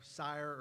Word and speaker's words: Seir: back to Seir, where, is that how you say it, S-E-I-R, Sire or Seir, however you want to say it,